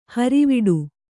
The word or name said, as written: ಹಾರಿವಡೆ